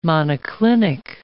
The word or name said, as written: monoclinic